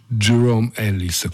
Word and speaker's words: Jerome: e il tutto va pronunciato come se si stesse balbettando, quindi Jerome Ellis, questo è più o meno il modo corretto di pronunciarlo